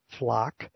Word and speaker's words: Flock: The Rutherford Institute is responding to reports that the Charlottesville Police Department hopes to adopt the FLOCK, F-L-O-C-K, please don't mispronounce it for me with a U, license plate recognition, which is LPR, system to prevent, deter, and solve crime.